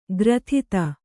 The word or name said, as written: ಗ್ರಥಿತ